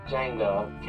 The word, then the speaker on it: Django: Django. Can you spell it? D-J-A-N-G-O. The D is silent. I know."